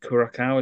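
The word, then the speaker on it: Curaçao: But there's a second game as well against Curacao.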